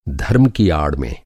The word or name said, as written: आड़